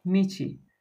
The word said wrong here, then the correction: miti: Yes, miti.